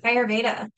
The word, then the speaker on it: Ayurveda: But Ayurveda.